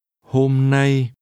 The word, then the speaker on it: hôm nay: Hôm nay. – This is pronounced with two mid-level tones.